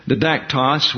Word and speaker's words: didaktos: Under the principle of didaktos, or didaktos, we have a tendency to emphasize the next to the last syllable. Didaktos, we'll pronounce it.